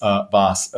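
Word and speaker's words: Waas: And so I'm wondering if, you know, the skier Vaas, V-O-S-S, if that used to be my name and they just changed it so that Americans would pronounce it the right way. But yes, Vaas.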